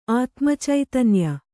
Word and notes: ಆತ್ಮಚೈತನ್ಯ: kannada

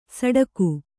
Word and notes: ಸಟಾಗು: saṭāgu

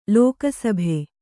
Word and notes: ಲೋಕಸಭೆ: lōka sabhe

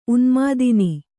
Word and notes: ಉನ್ಮಾದಿನಿ: Kannada

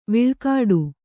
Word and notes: ವಿಳ್ಕಾಡು: Kannada

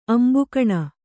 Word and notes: ಅಂಭಃಕಣ: ambhahkaṇa

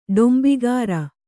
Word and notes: ಡಂಬಗಾರ: ḍambagāra

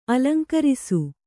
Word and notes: ಅಲಂಕರಿಸು: alaŋkarisu

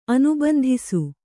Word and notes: ಅನುಬಂಧಿಸು: anubandhisu

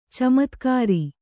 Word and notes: ಚಮತ್ಕಾರಿ: camatkāri

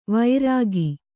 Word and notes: ವೈರಾಗಿ: kannada

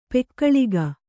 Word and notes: ಪೊಗಳಿಕೆ: pogaḷike

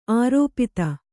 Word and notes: ಅರೆಪಾದ: arepāda; Kannada

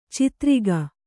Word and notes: ಚಿತ್ರಿಗ: citriga

